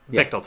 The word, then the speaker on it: Bechdel: I think I'm pronouncing that right. The Bechdel test? – The Bechdel test? – Bechdel test, yes.